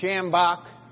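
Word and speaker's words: Schambach: His name was Shambach. Emphasis on the first syllable, Sham.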